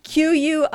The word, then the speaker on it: quinine: Quinine.